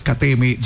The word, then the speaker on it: KTM Duke: Sí, se dice así, Andrés. KTM Duke.